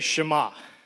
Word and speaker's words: Shema: Everybody say Shema. – Shema.